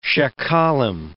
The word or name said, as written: sheqalim